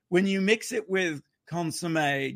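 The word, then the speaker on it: consommé: For our regular American listeners out there, it's consomme is how we pronounce it. Like, okay. Yeah. Consomme.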